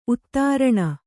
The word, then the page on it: ಉತ್ತರಣೆ: Kannada